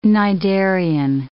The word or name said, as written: cnidarian